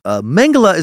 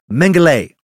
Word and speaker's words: Mengele: Also, quick note, there are two common pronunciations for this sadistic dipshit's name. Mengele is the most common Americanized version. That's the one I'll use, have been using, obviously. Mengele is the other.